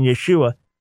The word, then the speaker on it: Yeshua: We feel good about this pronunciation, Yeshua, and find no evidence for Yeshua and the name.